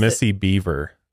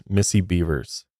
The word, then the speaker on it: Bevers: He's like almost shaking while reading through his statement here, and he also mispronounces her last name. Well, he says Missy Beaver. It's Missy Bevers.